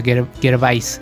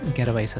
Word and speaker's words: Gervais: Eh, revisen su perfil en Instagram, es Ricky Gervais, Ricky con CK y EY, y Gervais, así como se escribe, como se escucha, Gervais, Gervais así.